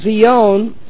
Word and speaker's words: scion: It is spelled in English S-C-I-O-N, which is related to the Hebrew word zion. So the term for when the graft actually takes place is zion, or we more commonly say it in English, Zion.